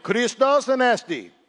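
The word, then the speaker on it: Christos Anesti (Χριστός Ανέστη): Christos Anesti. There's no accent in that. I want you to get your inner Mediterranean moving a little bit here, okay?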